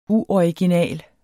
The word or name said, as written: UORIGINAL